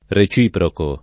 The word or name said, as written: reciprocare